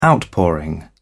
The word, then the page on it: outpouring: British English